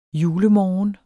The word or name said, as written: julemorgen